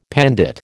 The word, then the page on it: pandit: American English